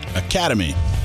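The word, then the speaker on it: Academi: He's the director of Academia. – Academy. – Well, who wrote Academy? – It's spelled funky, but it's Academy.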